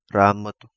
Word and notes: Ramotu: Kapingamarangi

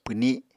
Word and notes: pwni: Apatani

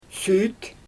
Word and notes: syt: Xyzyl

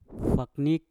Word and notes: waknik: mpur

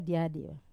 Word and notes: diadiva: Wamesa

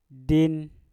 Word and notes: din: Mpur

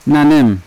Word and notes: nanem: Yawa

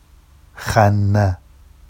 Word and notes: xanna: Vafsi

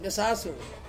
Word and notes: besasung: Ansus

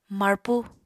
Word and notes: marpu: apatani

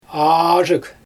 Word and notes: aazhyg: Xyzyl

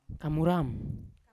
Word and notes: kamuram: Yawa

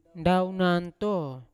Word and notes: ndaoganto: Yawa

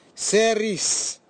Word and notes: seris: Nafe; Tanna

